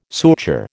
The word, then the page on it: suture: American English